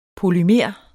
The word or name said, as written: polymer